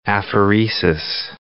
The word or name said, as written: apheresis